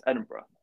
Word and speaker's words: Edinburgh: Am I saying it right, Edinburgh? – Yes, Edinburgh.